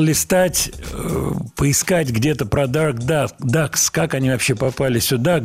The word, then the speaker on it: Dark Ducks: И от вас пришло сообщение, где упоминается японский квартет Duck Ducks. Dark Ducks, если уж так правильно произносить.